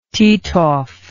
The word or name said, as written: Titov